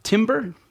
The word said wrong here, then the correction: Tambor: Is that right? – Tambor? – Yeah. – I believe it's pronounced Tambor.